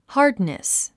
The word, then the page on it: hardness: hɑ́rdnəs; ハードネス